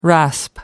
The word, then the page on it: rasp: American English